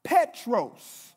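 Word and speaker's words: Petros (Πέτρος): Because here, right, in Greek, Peter's name, right, is pronounced Petros, right, which means stone.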